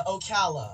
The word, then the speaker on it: Ocala: Is it pronounced Ocala or Ocala, Florida? – Hello? – Yes, that's you. – Hey, David. What's up? Yes, it's Ocala.